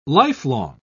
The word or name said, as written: lifelong